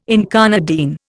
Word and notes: incarnadine: Indian

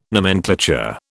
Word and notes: nomenclature: British English

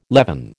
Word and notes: levin: American English

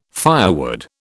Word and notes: firewood: British English

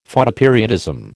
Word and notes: Photoperiodism: American English

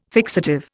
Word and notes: fixative: Indian

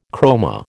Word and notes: chroma: American English

